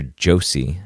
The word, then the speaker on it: Joshi: And in this article, and it's Dr. Joshi, I think that's how you pronounce it, J-O-S-H-I, Joshi, Joshi.